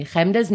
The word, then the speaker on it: Hemda: And by the way, and I pretend like it's not me, Hemda's name is pronounced Hemda. It's a Jew sound.